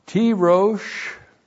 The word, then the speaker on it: tirosh: And then we have the noun tirosh, T long I R long O S H, Tirosh.